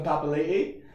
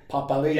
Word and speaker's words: Papali'i: Tell me about Dalton Papali. – So Dalton Papali'i? – Papali'i.